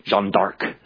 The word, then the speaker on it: Jeanne d'Arc: Jeanne d'Arc is really the way I hear people pronouncing it, which is her father's surname.